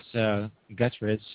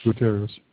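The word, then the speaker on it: Gutiérrez: Representative Louis Guterres, I'm probably pronouncing it wrong. – Guterres.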